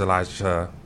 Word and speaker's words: Elisha: But you know, just being the second best man, I think about you know how uh, the mantle was passed from Elijah to Elisha. Yes, I said that right.